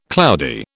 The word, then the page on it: cloudy: British English